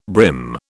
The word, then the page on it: brim: British English